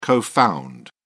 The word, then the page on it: co-found: British English